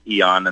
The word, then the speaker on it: Aeon: It's actually pronounced Eon, and the way I know that is I heard the founder call that the Eon patch, so it looks like Aeon.